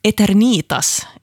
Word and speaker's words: Eternitas: Där kämpar jag på lite, men Eternitas är tydligen det korrekta uttalet här och namnet betyder evighet. Så tack till den lyssnare här som hörde av sig och berättade exakt hur det här ska uttalas.